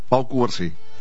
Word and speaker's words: Pau Cubarsí: Pau Cubar, sí.